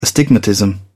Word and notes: astigmatism: British English

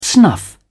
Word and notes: snuff: British English

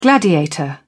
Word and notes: gladiator: British English

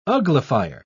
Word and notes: uglifier: British English

